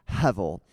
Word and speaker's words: hevel: It appears 38 times, and it is the Hebrew word hevel. If you were trying to spell that with English letters, it would be H-E-V-E-L, hevel.